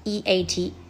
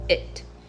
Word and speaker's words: eat: e a t eat 啊，我其实很多时候小朋友会念成 it。